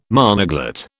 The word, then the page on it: monoglot: British English